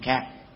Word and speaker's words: khang: Hoành khang thanh lãnh trắc thành phong, chữ khang ở trong tình huống này là đọc trẻ âm đó, là vì nó bị trắc bằng cho nên thay vì là kháng thì đọc là thành khang.